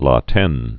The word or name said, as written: La Tene